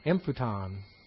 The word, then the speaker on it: emphuton: The emphasis is on the first syllable. Emphuton.